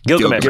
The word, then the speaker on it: Gilgamesh: Gargamesh? Is that it? Is that how you say it? – Gilgamesh.